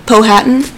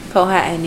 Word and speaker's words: Powhatan: Powhatan? – Powhatan, yeah.